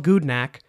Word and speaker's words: Gudnak: It's called Gudnak, and it's spelled G-U-D-N-A-K, and it has gone live on Kickstarter just yesterday.